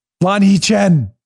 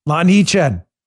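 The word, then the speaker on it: Lanhee Chen: The Republican, Lonnie Chen. You clearly said that wrong. No, nope, I didn't. Look him up. Lonnie Chen.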